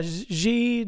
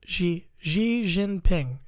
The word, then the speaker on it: Xi Jinping: Xi Jinping. – Yep, it looks like XI, but it's Xi. – Xi Jinping.